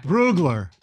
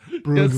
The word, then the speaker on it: Brugler: By the way, The Athletic, Dane Bugler, who Bugler, I don't know how to pronounce it. – Bugler. – What? Brugler. I said Bugler. – Brugler. Yes, you did. – I know, Brugler.